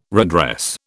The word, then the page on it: redress: British English